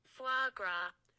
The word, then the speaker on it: foie gras: I'm just gonna play the proper pronunciation. – I'm on the edge of my seat. – Yeah. Here we go. – Foie gras. – So it even gives you F-W-A-A-G-R-A. Foie gras.